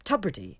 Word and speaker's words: Tubridy: The name Tubridy is pronounced tub, as in bathtub, not tube, as in tube of toothpaste.